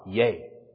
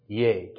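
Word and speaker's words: Iēsous: There's no Y sound in Greek, so they combined an I, which is E, iota, and then a long E sound, which is pronounced A, so E-A, That's how they did a why. Yay. Yay. Just run, say it several times fast and you'll get it. Yay. Yay. That's how you get the why sound.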